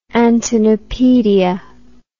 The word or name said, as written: antennapedia